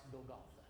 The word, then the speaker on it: Golgotha: But on down in the annals of time, at a place called the Skull, Sister Tracy, a place that we call Calvary, but in the Hebrew tongue it's pronounced Golgotha,